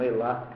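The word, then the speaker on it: hélas: Nomes próprios estrangeiros eh, pronuncia-se o S, e algumas vezes também eh, nomes franceses, como Elas, Elas, botar o S em Elas, como osso, os, como urso, urs.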